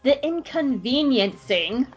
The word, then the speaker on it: The Inconveniencing: All right, The Inconveniencing.